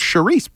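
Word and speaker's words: chouriço: The chorizo? Mm-hmm. – No, it's charisse Portuguese. – Oh. Mm. Oh, that's how they say it in Portuguese.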